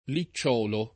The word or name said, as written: licciolo